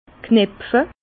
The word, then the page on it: knepfe: Bas Rhin; Herrlisheim